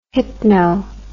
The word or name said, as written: hypno